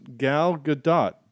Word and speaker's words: Gal Gadot: Her name is pronounced Gal Gadot. Gal GUH-DOTE. Godot? D-O-T-E. You pronounce the T, Godot or Godot.